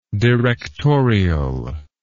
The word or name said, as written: directorially